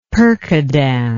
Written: percodan

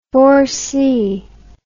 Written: four-by-two

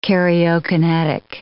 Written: karyokinetic

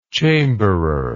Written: chamberer

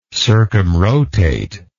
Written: circumrotatory

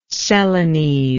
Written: celanese

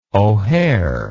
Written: O'Hare

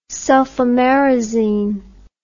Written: sulfamerazine

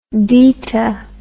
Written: dita

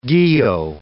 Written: guyot

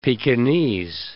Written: Pekingese

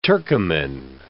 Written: Turkoman

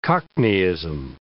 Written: cockneyism

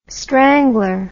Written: strangler

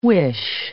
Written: whish

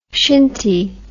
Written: shinty

